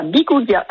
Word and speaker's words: Biguglia: Bonjour Christopher, bonjour Valentin, et oui c'est bien à Biguglia. – Voilà, on le prononce bien comme ça, c'est avec parfait.